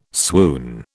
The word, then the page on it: swoon: British English